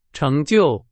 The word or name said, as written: 成就